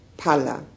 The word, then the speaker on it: phala: The actual benefit arises in the next two moments, which are called phala, P-H-A-L-A, which is fruit.